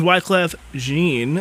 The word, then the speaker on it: Wyclef Jean: All right, ladies and gentlemen, that was Wyclef Jean, as Owen helpfully told me there, after I've been saying it wrong for so many years, but it's all right.